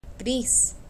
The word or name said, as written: gris